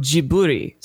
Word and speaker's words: Ghibli: Well, so apparently, according to the Japanese pronunciation, which has been, I'm going to butcher this, Kabushiki Gaisha Tsutagio Jiburi. So Jiburi would probably be the closest thing. So maybe it is Ghibli.